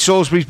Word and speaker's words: Salisbury: Or Pete Salisbury, I think he's pronounced Salisbury.